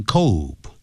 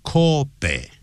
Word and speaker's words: Kobe: Kobe 因为这是日文所以日文的是它就叫念 Kobe 这样念好像呢就像清酒日本的这个清酒英文拼成 S-A-K-E